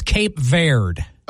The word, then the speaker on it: Cape Verde: So it's Cape Verde. – Oh, Verde. – Oh, Verde. Cape Verde. Thank you. – Oh, I thought it was Cape Verde, like V-E-R. – Silent E at the end.